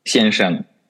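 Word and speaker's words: Сяньшен (先生): Так вот, смотри, я хотел тебя спросить, ты произносишь его именно так, делая акцентирование на двух слогах. Сяньшен, то есть действительно преждерожденный. Но я в обычной речи слышу редуцирование. Шен практически становится нулевым тоном, и просто все говорят сяньшен.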